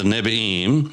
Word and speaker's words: Nevi'im: Then you had the prophets, the Nevi'im, or Nevi'im. The B and the V is just the difference in that is in pronunciation with a dot in the middle or not of one of the letters.